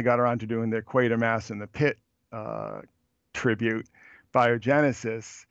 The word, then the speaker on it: Quatermass: I'm so glad you brought up, if it's pronounced Quatermass. – Quatermass, yeah.